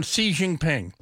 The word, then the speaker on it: Xi Jinping: which I've fallen on Xi Jinping. – Good morning, China. – From a certain podcast featuring a native Chinese Mandarin speaker who says XI is pronounced as C. Last name is Jinping.